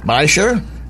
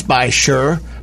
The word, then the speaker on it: Beishir: We are with Matt Walt with Bysher Audio Video. And that is spelt, because I was told to spell this correctly, because it is. It sounds by sure, but it's actually spelt B-E-I-S-H-I-R.